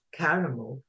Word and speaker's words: karamu: Kwanzaa, karamu. In Swahili, it's usually the next to last syllable of each word that has the emphasis in the word.